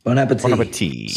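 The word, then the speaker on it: bon appétit: Bon appetit. – That's not right. – Nope. You absolutely don't pronounce the last T. It's French.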